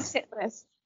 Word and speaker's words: Titlis: Okay, really quickly, we did have to Google and listen Google to how to pronounce the name of this mount to make sure it was, in fact, Mount Titlis, and it is. Mount Titlis.